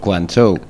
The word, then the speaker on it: Guangzhou: Guangzhou.